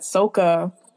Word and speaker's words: Sokka: You mean Sokka? Am I saying his name right? – Yeah. No, in the M. Night Shyamalan movie, he says Sokka. Oh, you mean Sokka? – My bad, Sokka.